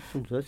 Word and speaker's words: Sun Tzu: Donc, l'auteur est Sun Tzu, ou je ne sais pas si on prononce comme ça, Sun Tzu. – Oui, Sun Tzu, ça va très bien.